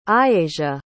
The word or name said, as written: aiyasia